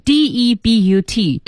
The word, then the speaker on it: debut: 注意这里有个单词啊，不知道大家知不知道 ,debut, 读音是 debut. – debut. – 对对对对。 – 听上像一个法语词啊。